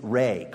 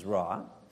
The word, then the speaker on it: Re: Now, in ancient Egypt, the sun was worshipped as a deity named Re, commonly mispronounced as Ra,